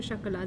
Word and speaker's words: chocolat: Wait, la maison du chocolat. The tea is silent, so.